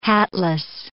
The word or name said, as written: hatless